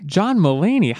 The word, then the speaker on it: John Mulaney: John Mulaney.